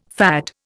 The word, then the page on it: FAD: Indian